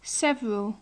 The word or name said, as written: several